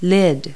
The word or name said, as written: lid